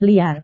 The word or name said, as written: liar